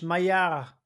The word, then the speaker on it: Maillard: It's like M-A-L-L. It's French. – Oh, it's French. Maillard.